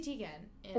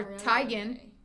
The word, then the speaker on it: Teigen: Chrissy Teigen. – Or Teigen. As you're supposed to say it.